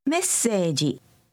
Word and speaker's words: メッセージ: メッセージ。ジ。ジ。セージ。セージ。メッセージ。メッセージ。 – Notice the slight pause in the middle of the word.